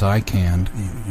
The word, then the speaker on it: ICANN: The first is ICANN.